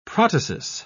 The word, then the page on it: protasis: British English